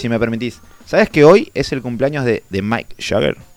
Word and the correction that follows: Mick Jagger: ¿Mike Jagger se dice? No sé cómo se dice. Mick Jagger. – Mick Jagger.